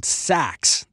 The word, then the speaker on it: Saxx: I recently discovered this brand of underwear called Saks. That's spelled S-A-X-X.